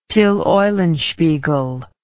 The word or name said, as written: Till Eulenspiegel